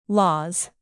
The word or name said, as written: laws